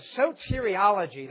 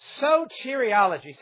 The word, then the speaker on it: soteriology: And that is the doctrine of soteriology. That's how you say it. Soteriology. Say that with me. Kind of sounds nice. Ready? Soteriology. Yes.